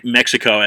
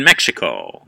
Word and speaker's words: Mexico: Well, with that, right, the Spaniards originally wrote Mexico as M-E-J-I-C-O. They pronounced it Mexico. And that's why the Mexicans pronounce their country Mexico. But the X, the X, is actually a sh sound. So it literally should be Mexico because of the Aztec tribes, the Mexicas.